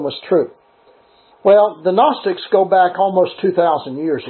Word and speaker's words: Gnostics: Only when you pronounce it in the form Gnostics, the G is silent.